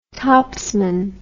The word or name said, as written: topsman